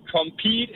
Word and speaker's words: Compeed: Du skal bruge uh, Compete eller Compete, jeg kan ikke huske præcis udtalen. Compete, C-O-M-P-E-E-D. Ja, Lige præcis.